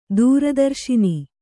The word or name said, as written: ದೂರದರ್ಶಿನಿ